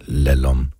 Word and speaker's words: LALOM: Det lyder lidt højtravende, men det er også meget godt måske, at de har forkortet det, så man bare skal udtale det lalom.